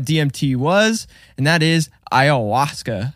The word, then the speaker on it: ayahuasca: A-Y-A-H-U-A-S-C-A. – Yeah. Emphasis on the hua. – Hua? Hua. – You got to really feel it in your throat, dude.